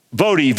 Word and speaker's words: Voddie: This term was quoted by, was coined, as far as I know, by Vodi Bakum. And by the way, I want to recommend a book to you. I'm going to recommend one more. Vodi, V-O-D-D-I-E, pronounced Vodi with a long O. Bakum, B-A-U-C-H-A-M.